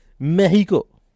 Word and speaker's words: Mexico: Mexico, to say it right. I'm not making fun. It's actually how it's pronounced.